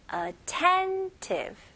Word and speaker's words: attentive: 오케이, 강세가 어디에 들어갈까요? 네, 두 번째 음절에 attentive, attentive.